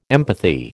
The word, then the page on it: empathy: American English